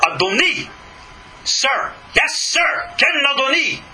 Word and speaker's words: Adoni: The same letters, Aleph, Dalet, Nun, Yud, are not pronounced Adonai, God, but Adoni, little vowel difference, and it's Sir.